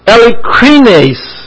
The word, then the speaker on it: eilikrines: May be sincere is the Greek word elikrines. E-I-L-I-K-R-I-N-E-S. That E sounds like an A because it's an eta instead of an epsilon in the Greek. And it's like a long and short E in the English. It's an adjective and it's a nominative plural masculine. And you have Heli, E-L-I, which means the sun's rays, and krines, which means to consider or to pass judgment.